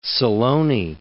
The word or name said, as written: Silone